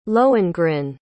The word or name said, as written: lohengrin